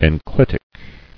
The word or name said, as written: Enclitic